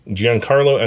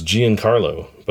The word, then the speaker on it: Giancarlo: Uh, and we have a name for uh, Giancarlo's Giancarlo Esposito. That's how he says his name. I heard him pronounce his name, and I always thought it was Giancarlo, but it's Giancarlo.